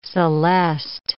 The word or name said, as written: celeste